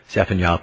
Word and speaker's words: Zephaniah: Zephaniah putting the accent on the last syllable, where it is Y-A-H. So we think of Yahweh first, even though it's the end of the name of Zephaniah.